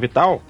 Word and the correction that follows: Vidal: Ele escreveu. Vidal, Vidal.